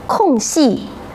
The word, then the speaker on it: 空隙: 这两个词我们再一起来读读，空隙、弯曲。读了词语，你们有什么发现？对，这里面藏着两个多音字呢。当留出位置时，读作四声空。